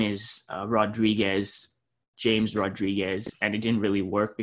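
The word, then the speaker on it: James Rodríguez: Is it James Rodriguez? James Rodriguez? What's the correct pronunciation of his name so that we can get it right, you know, as we hopefully have him locked up here shortly? – It's James Rodriguez.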